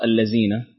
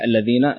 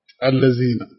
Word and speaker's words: الذين: وأيضا في الذين يعني خطأ شائع الذين تنطق بالخطأ الذين حرف الزاي. والصحيح الذين اللسان بالضبط حرف الذال يكون اللسان خارج. ممتاز. فتنطق الذين. ممكن تجيبها لنا؟ – الذين.